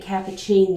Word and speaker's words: Capucine: She pronounced it Capucine, not like the coffee drink.